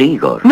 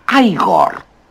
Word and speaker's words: Igor: Usted debe de ser Igor. – No, se pronuncia Igor. – A mí me dijeron que era Igor. – Pues estaban equivocados, ¿sabe? – Que no fumes de eso, tú.